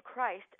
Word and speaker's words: Christ: And in German, the name Christ sounds like Chris with a T at the end.